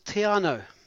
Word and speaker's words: Te Anau: Well, Cameron, that there is the place that I'd love to be right now because I own a home there, and it is Te Anau. – Hey, well done. Ka pai, ka pai. Brilliant pronunciation.